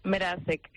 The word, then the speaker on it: Merasek: Merasek. – Ajá, o sea que es como si estuviera entre la M y la R una E, ¿no? – No. Sin la E.